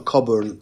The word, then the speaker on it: Cockburn: Sì, vi dico, guarda, Illinois, giusto per spendere a ah, Coffee Cockburn, che vabbè loro pronunciano Coburn, quindi Coffee Coburn che è Bud Spencer, quindi gioca con Bud Spencer in campo.